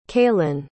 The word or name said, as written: kalin